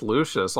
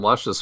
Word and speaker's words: Lucius: If he did, I did not read it, but I'm just shocked that it's pronounced Lucius. All this time it's been Luscious Fox to me.